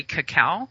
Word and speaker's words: cacao: It's not chocolate powder, but the proper pronunciation is actually cacao.